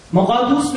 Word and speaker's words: مقدوس: یوم کیپور بزرگترین روزه یهودیان است که با نام‌های چون یوم عدیل یعنی جلیل و با عزت، یوم مقدوش، مقدوش به جای شسه بذارید، مقدوس بگید، مقدس، روز مقدس، یعنی روز، و یوم عاشورا هم مسما میباشد.